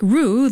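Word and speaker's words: ro: In Norwegian, the word row is R-O, pronounced like roux.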